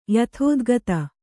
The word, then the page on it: ಯಥೋದ್ಗತ: yathōdgata